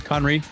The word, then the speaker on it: Conry: Conry, yep.